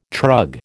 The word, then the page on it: trug: American English